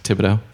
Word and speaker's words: Thibodeau: That's Thibodeau. – And the H is? – The H is silent.